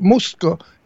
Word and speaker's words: Musto: Michael, well, it's Muzo, we pronounce it. Michael, you know what his last name means in Italian? Face. Muzo is a face. So his name is Michael Face. – But actually his last name's not Muzo, it's Musto. – Well, that's how the Americans say it, but Musto is in Italian. – M-U-S-T-O. – In Italian, yeah. It's different. Our vowels are A-E-I-O-U, and the E and the A are different than in American.